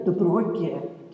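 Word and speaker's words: De Bruyckere: But first, I want to start with you, because you've had the longest association with Belinda de Bruyckeer. Is that how you pronounce her name? – It's quite difficult because it's Flemish. It's Belinda de Bruyckeer.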